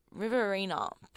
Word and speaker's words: Riverina: We have Riverina by The Temper Trap.